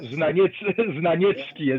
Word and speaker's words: Znaniecki: Znaniecki, or Znaniecki, as we pronounce this, because Znaniecki was in Poznań also between two world wars.